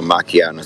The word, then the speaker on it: macchiato: Macchiato. Not the H is silent.